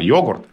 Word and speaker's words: йогурт: И сколько тогда лингвисты не говорили о том, что, помилуйте, какое теперь правильное, это старая норма, это старое заимствование, а новая норма йогурт.